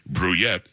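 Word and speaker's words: Brouillette: I'm telling you, I want to say it a different way, the way it looks. Bruyette is how you say it.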